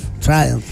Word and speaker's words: Triumph: ¿Cómo se dice? Triumph, ya.